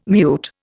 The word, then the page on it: mute: Indian